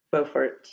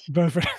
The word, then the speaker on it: Beaufort: How did you get here and end up doing what you're doing in an antique shop and pronounce it Beaufort, Beaufort? I get it mixed up. Beaufort. Beaufort.